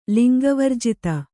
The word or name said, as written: ಲಿಂಗವರ್ಜಿತ